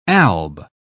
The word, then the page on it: alb: British English